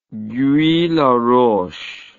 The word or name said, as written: guy laroche